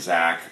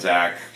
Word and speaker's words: Czach: You can just look up my name, Brian Czach, on any of those platforms. My last name is spelled C-Z-A-C-H, so it's Brian, B-R-I-A-N-C-Z-A-C-H, .com, and Facebook, Brian Czach, Instagram, @BrianZach, Twitter, @BrianZach.